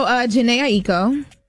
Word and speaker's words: Jhené Aiko: So, Jhené Aiko. Told y'all I said it right. – Yes, you did.